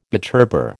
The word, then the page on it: butterbur: American English